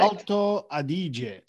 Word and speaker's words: Alto Adige: Alto Adige.